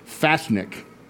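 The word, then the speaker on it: Fasnacht: It's pronounced Fasnik, and it's I think originally started in Switzerland at a town called Basel.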